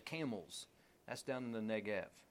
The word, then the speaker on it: Negev: But even when we were in Israel, all the signs were spelled with a V, not with a B. So it's Negev. He heads with his family and everyone that he has down into the Negev.